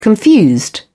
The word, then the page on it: confused: British English